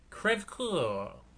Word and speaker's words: crève-cœur: And in it was the French term, which I will pronounce correctly, crève-cœur, which I believe means broken heart, right? – I think so.